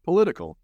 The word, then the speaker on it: political: Ojo, ¿cuál es la clave aquí? La T. Cuando leemos la palabra político en inglés se escribe political. Political. ¿Qué está ocurriendo con la T? Lo mismo que ocurre el fenómeno de la T.